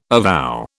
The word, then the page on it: avow: British English